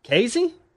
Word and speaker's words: Kazee: Anyway, him and Kyle Fuller and DeMonte Kazee? Mm-hmm. Is that how you say his name? K-A-Z-E-E? – Kazee!